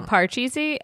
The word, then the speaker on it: parcheesi: Legitimately, some boxes say Pachisi, and some say parcheesi, and I always thought it was my parents saying it wrong, but as it turns out, no.